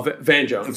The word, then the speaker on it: Van Jones: I think it's Vaughn Jones. I hope I'm not mispronouncing his name. Who mentioned something, and I think... – Oh, Van Jones. – Van Jones, sorry.